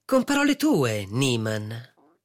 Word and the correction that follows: Neumann: Neumann. – Come? – Neumann. Si pronuncia Neumann.